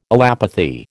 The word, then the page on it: allopathy: American English